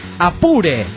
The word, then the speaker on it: Apure: Apure se pronuncia Apure, valga el acento.